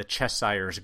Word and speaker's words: Cheshire's: But this time we feel that you should actually pause the episode, open up Instagram, and check out the Cheshire's Grin. That's the_Cheshire. Am I saying that right? Cheshire's. – Cheshires. – _Grin. Don't go by how I pronounce it. Go by how Barb spells it.